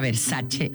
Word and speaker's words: Versace: Por ejemplo, Versace, cuando la marca Versace es italiana. correcto, se tiene que pronunciar Versace.